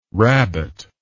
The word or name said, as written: rabot